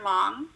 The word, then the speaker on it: Luong: Long. The last name is spelled L-U-O-N-G. Everyone always gets it wrong.